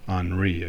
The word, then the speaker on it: Henri: I thought it was Henry. It's Henri, I guess. That's how it's pronounced.